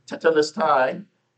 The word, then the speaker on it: tetelestai: The Greek word here is tetelestai, and for those of you that want to look into that, it's spelled T-E-T-E-L-E-S-T-A-I.